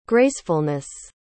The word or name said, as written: gracefulness